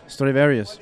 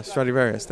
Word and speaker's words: Stradivarius: It is the violin, but how do you pronounce it? – Stradivarius. – Yeah, Stradivarius. There you go.